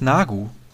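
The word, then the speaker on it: Neagu: Christina Nergu heißt Nagu das E wird wohl nicht ausgesprochen, habe ich gelesen.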